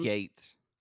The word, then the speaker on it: Gaetz: I think it's Gates. Mike Gates. – Well, his name is fucking stupid, like his hair. – It's spelled stupid. It's spelled Gaetz. – Yeah, Gates, whatever. Matt Gaetz, and I'll repeat it, who looks like his daddy is a lawyer and bails him out regularly for DUIs.